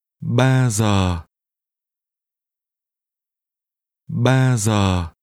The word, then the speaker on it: Ba: Ba. Ba. Ba. – 3 is pronounced with a mid-level tone.